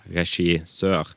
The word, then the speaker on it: regissør: Um, og, men han har jo rett også, da, i at eh, det riktige er regissør.